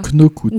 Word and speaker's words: Knockout: Mais on ne prononce pas le K, là. – Knockout.